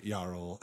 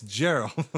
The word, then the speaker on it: Gerald: His name is Gerald, and he says, Mr. Jarl. And he goes, why do you insist on pronouncing my name Jarl without the J? It's Gerald.